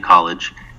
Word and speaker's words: College: You could find me there, Anthony College, and my last name is spelled just like the school, just like a college, C-O-L-L-E-G-E.